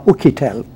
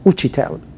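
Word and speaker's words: Uchitelle: این رو نویسنده یا خبرنگار و یا اقتصاددانی به اسم لوئیس اوچیتل یا اوکیتل، اوچیتل بیشتر تلفظ میشه، که خبرنگار اقتصادی نیویورک تایمز نوشته.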